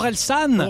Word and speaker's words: Orelsan: I think I'm pronouncing it wrong. I think it's Horizon. – Ah, Orelsan. – Orelsan. – Horizon means horizon, so it was a bit weird.